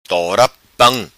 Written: Tora Pam

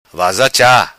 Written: vaza cha

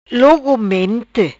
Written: Logomente

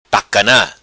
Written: Tacana

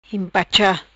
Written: Impacha

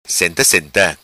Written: Senta Senta

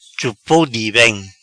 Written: Chupou Di Bem

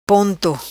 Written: Ponto

